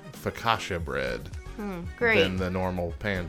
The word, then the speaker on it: focaccia: Hey, I'm proud of you for pronouncing focaccia correctly.